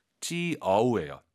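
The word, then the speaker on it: 就: 지우가 아니라 아이유 사이에 오 넣어서 지우. – 지어우예요. 지어우.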